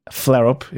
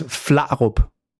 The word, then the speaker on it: Flarup: Flareup is how the Americans pronounce it most often. – Yeah, I just so I can get it on record. What is the correct pronunciation? Because I know you gave me permission to use the dumb American pronunciation, that's so okay. It's Flareup. So that's awesome. That's the Danish pronunciation.